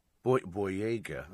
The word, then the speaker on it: Boyega: She's interviewing John, is it John Boyega? Boyega.